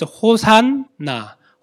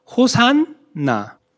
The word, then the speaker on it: Hosanna: Its right pronunciation is Hosanna. Hosanna.